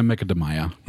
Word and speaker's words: Macadamia: Das wird ja eigentlich Macadamia ausgesprochen. – Ja, auf eine Macadamia.